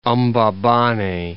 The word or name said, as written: Mbabane